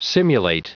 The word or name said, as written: simulate